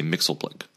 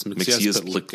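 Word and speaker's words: Mxyzptlk: Now, why in the cartoons on television did they always say Mixelblick? – Because why would they bother to pronounce it correctly? It's Mixiezpitlick. It's just like it's spelled.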